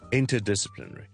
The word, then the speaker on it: interdisciplinary: 如果你讲的时候你不将两个字都重呢，听下去呢是会有点难的，因为有很多音节 inter 两个啦， disciplinary 已经有六七个 syllables 呢，你如果不重两个呢是有点难的。听一次 interdisciplinary，譬如我们说 we're running an interdisciplinary program or a course 这样。